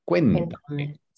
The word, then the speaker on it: cuéntame: Entonces, es con acento en la E. Cuéntame.